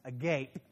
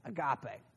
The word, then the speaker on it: agape: I know that spelled that spells agape, but they pronounced it agape.